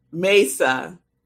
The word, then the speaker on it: Mesa: So Shay and Linda are telling me the pronunciation. Mesa, the Grand Mesa. All right, long A.